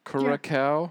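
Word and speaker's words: Curaçao: Curacao.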